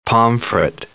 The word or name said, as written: pomfret